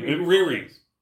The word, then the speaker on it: Riri: Riri.